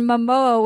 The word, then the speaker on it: Momoa: My boyfriend described Leo as Sean William Scott in a Jason Momoa wig, and now I can't unsee it. – Yeah. – I like that. Sean William Scott. Am I saying his name right?